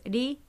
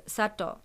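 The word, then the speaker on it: ri (里): It has two pronunciations. One is ri and one is sato.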